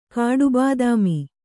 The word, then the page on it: ಕಾಡುಬಾದಾಮಿ: kannada